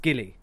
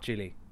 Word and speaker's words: Gilly: So it's Gilly, not Jilly.